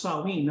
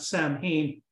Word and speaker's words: Samhain: It's pronounced Samhain, not Samhain, on October the 31st.